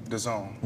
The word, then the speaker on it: DAZN: And I subscribed to DAZN, mm-hmm. if I'm saying that correctly. – Yeah, that's it.